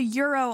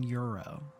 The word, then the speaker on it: gyro: I think it's pronounced euro. – Spell it. – G-Y-R-O. – G-Y-R-O. – So it's euro.